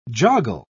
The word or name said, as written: joggle